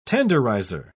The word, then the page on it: tenderizer: British English